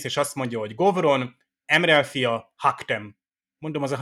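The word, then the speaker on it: M'Rel: egyébként Emrel nek ejtik az angolban, meg a magyarban,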